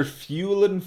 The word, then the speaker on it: Fuelan: Professor Fuelan Fossil was at the men's... – Hold on. How do you spell that? – F-U-E-L-A-N. Fuelan. – F-U-E-L-A-N? – Fuelan Fossil.